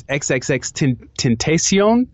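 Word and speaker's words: XXXTentacion: I heard how you pronounce his name is XXX Tentacion. Like, apparently he has, like, some Spanish.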